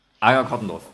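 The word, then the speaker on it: Anger-Crottendorf: Das ist Anger Kottner. Nee, ich kann es nicht mal aussprechen. Anger Kottendorf. Jetzt, ich habe es hinter mich gebracht.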